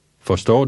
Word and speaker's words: forstår: Forstår du? Forstår du? Forstår du? – Note the stress is on the second part of forstår.